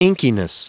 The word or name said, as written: inkiness